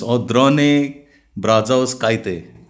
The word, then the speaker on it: Audrone Brazauskaite: So what we're going to have here is give a warm welcome to Miss Audrone Brazauskaite.